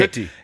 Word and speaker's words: Filthy: Habe ich das TH eigentlich schön ausgesprochen? – Wunderbar. – Wenn ich also Filthy sage. – Ja. Filthy. Du musst, Filthy. Musst etwas, Filthy. Mehr, etwas mehr spucken dabei.